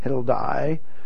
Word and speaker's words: Heldai: "Then the word of the Lord came to me, Zechariah, saying, 'Receive the gifts from the captives, from Heldai, here in Hebrew is pronounced just like an I, so it's Heldai,